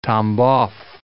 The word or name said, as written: Tambov